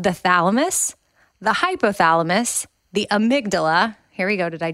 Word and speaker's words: amygdala: Amygdala, which that's the part of the brain that's spelled A-M-Y-G-D-A-L-A. So it looks like amygdala, but it's amygdala.